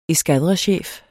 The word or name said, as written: eskadrechef